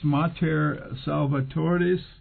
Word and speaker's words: Mater Salvatoris: And now, just to let our audience know again, it's Mater Salvatoris, more of American pronunciation. You could go to Mater, M-A-T-E-R, Salvatoris.